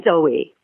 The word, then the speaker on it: Zowie: So, yeah, I was really glad that you covered having um, Angie and uh, do you pronounce it Zoe or Zowie? – Yes, I say Zoe.